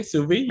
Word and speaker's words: sous vide: Did I pronounce it properly, sous vide? – No, yes.